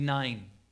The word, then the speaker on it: Nain: There's a large group of people following Jesus, and they are coming into this town called Nain. That's kind of how we pronounce it. It's in the Greek, it's actually Nain.